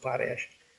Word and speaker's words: Potash: You know, when you write the checkout, it's the same, you know, but, you know, we don't, I like the conversation and so that's how we pronounce. We pronounce it Potash.